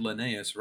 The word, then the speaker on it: Linnaeus: It's also the first time you've ever said Linnaeus right on the first go. – Have I been saying it wrong? – You've said it wrong a billion times, and we always correct you. And this is the first time you've done it like of your own volition.